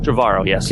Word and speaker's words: Trevorrow: Now, whilst we're talking about IMAX and Star Wars, what do you make of the how are you pronouncing his surname, the director of episode 9? Is it Colin Trevorrow? – Trevorrow, yes.